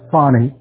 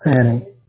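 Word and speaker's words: Fani: It's pronounced Fannie, not Fannie.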